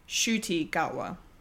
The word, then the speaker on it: Ncuti Gatwa: Um, but it's actually pronounced Ncuti Gatwa. Not yeah. yeah, not the way I not the way we all said it last week because we were like we've never heard of this person.